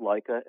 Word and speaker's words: Lika: Yeah, our product is called Leica, L-I-K-A, Leica burgers, Leica meatball, Leica meatloaf.